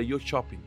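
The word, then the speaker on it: Jönköping: Mas pelo, se eu não me engano, a pronúncia correta foi até que a Maria fala que a Maria tá lá no, na Suécia, né? Ela falou que, o, que os suecos lá falavam que era Yo Shopping.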